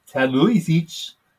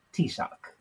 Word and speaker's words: Taoiseach: Now, when you look at the spelling, it looks like Taoiseach, but it's pronounced Taoiseach. – Yep. – A-O-I in Irish is pronounced E. S-E is pronounced Sh. And A-C-H is pronounced Ak. So Taoiseach. Taoiseach.